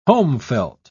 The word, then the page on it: homefelt: British English